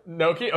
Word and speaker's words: gnocchi: Um, G is It's a lot easier when you're gnocchi. – not gnocchi. Okay, yeah, because it's a silent G.